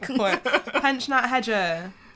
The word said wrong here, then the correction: Heger: Pensionnat Heger. Pensionnat Heger.